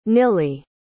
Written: nilley

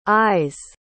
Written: ayes

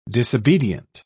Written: disobedient

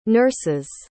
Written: nurses'

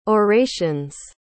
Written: orations

